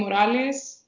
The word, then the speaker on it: Morales: You spell it E-L-I-S-A underscore last name Morales, M-O-R-A-L-E-S underscore PhD.